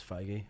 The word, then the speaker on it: Feige: Apparently it's Feige. Oh, I always thought it was Feige. – I always thought it was, yeah. – Or like as beige. Like, beige. – Yeah, Feige.